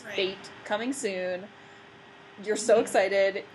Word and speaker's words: F8: And it's F and then the number 8. And it's pronounced Fate.